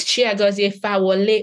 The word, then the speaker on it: Chiagozi Fawole: And I just chuckled because anybody hearing how I just pronounced my name will have to basically laugh. They're like, no, it's Chiagozi Fawole.